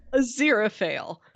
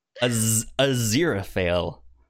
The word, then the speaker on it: Aziraphale: Aziraphale. – I'm going to fail at saying his name. Aziraphale.